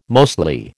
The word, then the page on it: mostly: American English